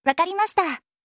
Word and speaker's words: わかりました: وکاریماشتا.